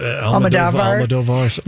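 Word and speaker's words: Almodóvar: Almodovar. – Almodovar.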